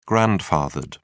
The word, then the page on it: grandfathered: British English